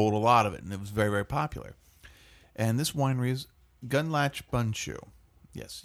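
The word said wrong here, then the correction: Gundlach Bundschu: I'm sorry, Gunlock Bunshu. What did I say? – Watch.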